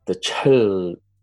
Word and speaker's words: Chil: So it's the Chil, you know, no one could say that properly here. Mm-hmm. Even in the way it was translated at immigration, it was translated with an O. So I was called Chol for a lot, and that was not correct. The closest pronunciation is Chil. So I just stuck with Chill and it stuck.